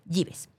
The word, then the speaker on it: Jeeves: Gives. Se escribe J-E-E-V-E-S. Así se escribe. Lo vamos a pronunciar Gives.